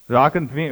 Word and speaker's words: Drachenflieger: Did anyone know how to pronounce that word? I heard it. Drachenflieger. Oh, good. I would have said Drachenflieger or something like that. But well done.